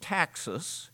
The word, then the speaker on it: taxis: And so when he talks about proper order, this is a military word pronounced taxis, and it means order.